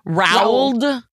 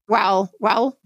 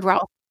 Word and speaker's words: Roald: Roald? Rowald? – Roald. I always heard Roald. Roald. I always heard Roald. It's an odd spelling, R-O-A-L-D.